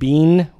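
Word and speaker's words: bin (בין): This Hebrew word is the word bin, which is spelled B-I-N, but it's pronounced like chili bean.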